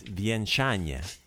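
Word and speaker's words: vjenčanje: Okay, the word for wedding is vienchanje. Vienchanje. Wieńczanie. Wieńczanie. So, uh, accents on the second syllable.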